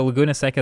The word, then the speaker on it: Laguna Seca: But then shortly thereafter, the shorter track called Laguna Seca. Am I saying that right? I'm going to just mispronounce a lot of things during this. – I think Laguna's right. – All right. Well, so Laguna Seca is a shorter track.